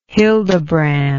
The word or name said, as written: hildebrand